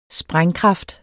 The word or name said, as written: sprængkraft